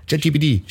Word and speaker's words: ChatGPT: Das ist die mitunter schwierige Aussprache vom KI-Chatbot ChatGTP. – Ja. – ChatGPT.